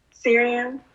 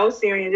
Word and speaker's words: Sirian: Sirian. Not Osirian, just Sirian.